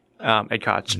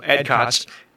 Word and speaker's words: Ed Koch: Ed Koch. – Nailed it. – Great. Hopefully, maybe he could say my last name. But Ed Koch.